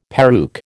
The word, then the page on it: peruke: American English